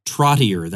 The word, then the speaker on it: Trottier: This is Ross Trottier. That is actually how he pronounces his last name. I'm not just mispronouncing it. That's how he says it.